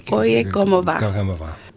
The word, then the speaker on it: Oye Como Va: Oye Como Va.